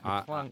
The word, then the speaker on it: McClung: I know it's McClung.